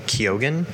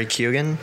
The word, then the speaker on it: Keoghan: Keoghan. Barry Keoghan.